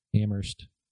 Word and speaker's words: Amherst: Amherst. – The H is silent, bro.